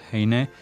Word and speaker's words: Heine: Muy bien, pues esta es la obra del matemático judío-alemán Heinrich Eduard Heine. Hein, como quieran pronunciarlo, lo habrán escuchado de ambas maneras.